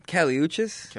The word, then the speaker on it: Kali Uchis: Kali Uchis.